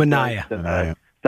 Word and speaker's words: Manaea: Mets pitcher Manaea.